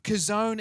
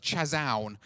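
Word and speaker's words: kazon (חזון): Kazon is this idea, it says, it looks wrong, doesn't it? It's like chazown. But trust me, that is actually pronounced kazon.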